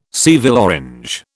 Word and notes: Seville orange: British English

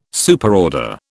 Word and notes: Superorder: British English

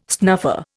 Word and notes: snuffer: Indian

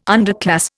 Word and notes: underclass: Indian

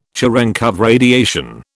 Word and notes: Cherenkov radiation: British English

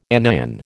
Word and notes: anion: American English

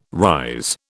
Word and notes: rise: British English

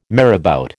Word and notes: marabout: American English

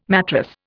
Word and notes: matrass: Indian

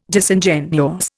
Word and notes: Disingenuous: Indian

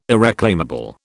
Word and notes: irreclaimable: British English

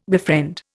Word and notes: befriend: Indian

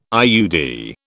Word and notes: IUD: British English

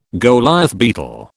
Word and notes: Goliath beetle: British English

